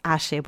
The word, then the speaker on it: Aye: No, es con Y. Ay, bueno, pero sería Aye. No, no es Aye. Es Aye, porque es un nombre argentino y se pronuncia así.